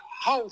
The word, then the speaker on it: hou: Je bedoelt te zeggen dat ik hou van cassette. – Je houdt van cassette. Ja. Waarom? – De OU in het Nederlands, die spreek je niet uit als OU, maar als OU.